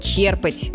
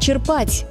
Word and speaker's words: черпать: Черпать воду или черпать? Вот тут многие спотыкаются. Правильный вариант – черпать, с ударением на первый слог. И это не рекомендация, это норма, закрепленная в орфоэпических словарях. Интересно, что в разговорной речи часто звучит «черпать», возможно, по аналогии со словом «черпак», где ударение действительно на второй слог.